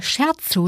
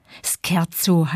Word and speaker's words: Scherzo: Wer in der Musik von einem Scherzo spricht, outet sich gleich als Laie. Scherzo heißt das, italienisch ausgesprochen mit K.